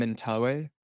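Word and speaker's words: Mentawai: So I pronounce their name Mentawe. That's kind of halfway between what they say. They call themselves the Mentawe, and then Westerners call them the Mentawais.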